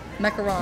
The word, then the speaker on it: macaron: Macaron. – Am I saying it right? – Macaron. – Yes.